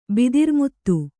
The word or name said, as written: ಬಿದಿರ್ಮುತ್ತು